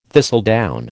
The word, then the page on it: thistledown: American English